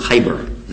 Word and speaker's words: Khaybar: It's on the authority of Ali radiallahu anhu, and he said at the Battle of Khaybar, Ghazwatul Khaybar. Is that how you say it in Arabic? Yes.